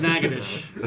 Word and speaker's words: Natchitoches: You mean it's not Nacogdoches? – No, apparently it's Natchitoches.